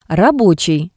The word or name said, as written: rabotsii